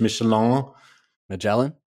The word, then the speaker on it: Magellan: Magellan. – Magellan, that sounds like a tire.